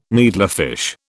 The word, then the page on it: needlefish: British English